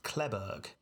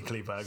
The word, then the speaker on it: Kleberg: Now, a rich Texas man named Kleberg, K-L-E-B-E-R-G, Kleberg. – I think it probably pronounced Kleberg, but it's obviously Kleberg. – Kleberg. I mean, it's, you've got to say it in a Texas accent for a start.